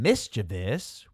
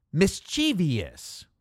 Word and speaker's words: mischievous: If you keep this concept in mind, you will never go astray when deciding between mischievous with three syllables and mischievous with four. The shorter, more transient version is usually the righteous one.